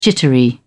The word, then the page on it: jittery: British English